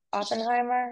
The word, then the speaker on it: Oppenheimer: And actually, I really want to see Oppenheimer.